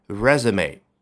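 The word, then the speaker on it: résumé: 说一说 resume 啊，这个发音就让你想起来应该是从法语当中借过来的。果然如此嘛，再来一遍，它的发音比较的奇怪。 resume，好，一定要记得关注我的微信公众号 英语研习社。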